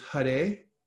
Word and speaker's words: Hare: And it's first it starts with Hare, and that's how you pronounce that, H-A-R-E.